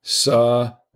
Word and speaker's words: Savoia: If you read it slowly, Savoya, it's phonetic.